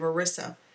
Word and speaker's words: Orissa: In A Necessary Evil, Wyndham and Banerjee travel to one of the princely states in the state of Orissa. Please correct me if I'm mispronouncing. – I think you're pronouncing it spot on.